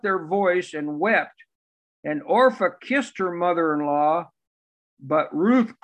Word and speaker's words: Orpah: What, somebody, how do you pronounce her name? Orpha? Yeah, Orpha, O-R-P-A-H, Orpha.